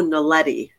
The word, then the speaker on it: naledi: naledi. – Spell it. – N-A-L-E-D-I, the naledi.